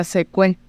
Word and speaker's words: Xecué: Eh, la palabra original en Quiligua se escribe con S y se pronuncia secue.